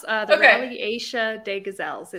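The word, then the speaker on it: Rallye Aïcha des Gazelles: It's the Rally Asia de Gazelle. I don't know. – Yeah, that was pretty close. Rally Asia de Gazelles. It's French.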